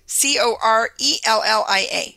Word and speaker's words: Corellia: Is it C-O-R-E-L-L-I-A? – Corellia. Corellia. Corellia.